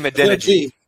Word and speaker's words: Adeniji: And I think uh, Hakeem, uh, what is it, Adeniji? Adeniji? – Yeah. I never, I'm terrible at pronunciations. Hakeem Adeniji. G, that's correct, uh, would probably be in line uh, for the start.